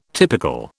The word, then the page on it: typical: British English